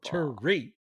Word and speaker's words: Thierry: You gotta put the emphasis on the second syllable. – Thierry. – It's French.